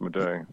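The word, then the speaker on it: Madej: Did you see that interview that Dr. Carrie, is it Majay? – I heard it pronounced Majay.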